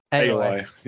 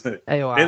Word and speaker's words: Aoi: AOI. Yes. AOI. It is literally how it's spelled. AOI.